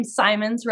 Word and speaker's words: Symonds: I just want to say, uh, last name Symonds rhymes with diamonds.